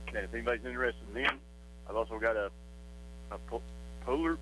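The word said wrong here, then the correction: Poulan Pro: I think I'm saying it right, chainsaw. – Poland Pro. – Poland Pro. Thank you, Drew.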